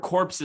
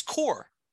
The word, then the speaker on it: corps: Forward corpse is also like CORPS. That is a commonly used phrase. – It's core. – But people also say corpse. Like they shouldn't. That's not how it's pronounced. – CORPS. – Look, the core of discovery. Yeah. CORPS. – Not CORPSE, like a dead body. I'm saying like corpse. – Yeah, that's what I mean. Yeah, without the E, it's pronounced core.